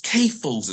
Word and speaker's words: CAFOs: CAFOs is how we pronounce it.